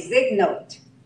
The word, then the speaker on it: zygote: A zygote, or a zygote. Z-Y-G-O-T-E. How do you pronounce that? – Zygote. – Huh? – Zygote. – Zygote. Okay. Zygote.